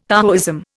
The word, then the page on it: Taoism: Indian